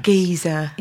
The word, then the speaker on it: Giza: I want to go to Giza. – Yes. Yes. Mm-hmm. Is that how you pronounce it? – Yes. Giza.